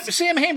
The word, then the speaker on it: Samhain: Yeah, I noticed that in that Gaelic sort of tongue, that Celtic Gaelic tongue, it's actually, pronounced Samhain. Which makes no sense, but then again, neither does the name Siobhan or the name Sean, even S-E-A-N.